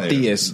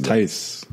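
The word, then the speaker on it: Theis: No, oh, Theus. – Theus. – Okay. – That thing is Tice. – Tice. It is Tice. – Yeah, it is Tice. It's spelled like Theus, though.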